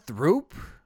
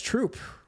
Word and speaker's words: Throop: So is that Throop? – No, it's Troop. – It's Troop. – Which I didn't... Yes. – Okay, so the H is silent.